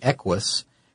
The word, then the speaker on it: Equus: And the reason Hyundai was invited to the table is because of their, at the time, brand new model, which was the Equus. And I think I'm hoping I'm saying that right. It's a weird name. It's EQUUS, I think. – Yeah, you're saying it.